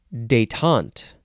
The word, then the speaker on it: détente: This word is détente. Emphasis on the second syllable.